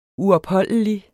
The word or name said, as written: uopholdelig